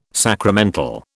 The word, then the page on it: sacramental: British English